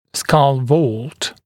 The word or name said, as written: skull vault